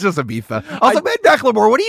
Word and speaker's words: Ibiza: Is Ibiza how you pronounce Ibiza? – It's Ibiza.